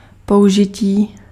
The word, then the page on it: emploi: France; /ɑ̃.plwa/